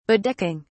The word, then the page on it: bedecking: British English